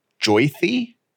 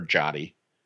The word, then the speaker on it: Jyoti: because her name is Joythy, okay, but it's pronounced J-Y-O-T-I, pronounced Joythy. – You mean it's spelled, not pronounced? – It's spelled J-Y-O-T-I, yeah. It's pronounced Joythy. And I really hope I'm saying that right, because for the past 20 years, she has let people just call her Jotty. – No. – 20 years, because her name is spelled, like phonetically, it looks like Jotty, rhyming with potty, right?